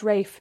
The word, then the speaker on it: Ralph: I was like, no, it's pronounced Rafe. And then he was like, how could it be pronounced Rafe when it's written as Ralph?